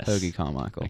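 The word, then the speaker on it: Hoagy Carmichael: But some people claimed it's named after the song Lazy Bones by, I'm really going to mispronounce this, Hoagie Carmichael. Do you think that's right? – Yeah, Hoagie Carmichael.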